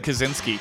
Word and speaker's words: Kaczynski: I said Kaczynski. – Oh, okay. – Like the Unabomber, like Ted Kaczynski. Yeah. I'm a big fan of Kaczynski.